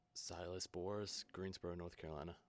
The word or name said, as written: Sylas Boris